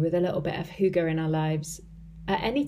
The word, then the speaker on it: hygge: It's spelt H-Y-G-G-E and pronounced hygge, H-O-O-G-A, I guess.